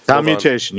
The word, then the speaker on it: commutation: Which is go back to Google and look at the pronunciation again. – Commutation. You're good.